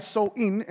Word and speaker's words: Samhain: Y ellos creían que el día antes del Año Nuevo, que es el octubre 31, un dios que se llama Samhain, se deletrea Samhain, pero en sí se pronuncia So-in en el lenguaje de ellos.